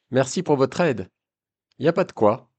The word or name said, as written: ya pa de koi